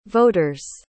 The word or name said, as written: voters